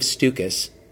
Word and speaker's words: Stukas: We're talking about allergies and allergy myths with Dr. Dave Stukas. Rhymes with mucus. That's how he told me to pronounce it.